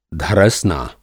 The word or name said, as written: धरसना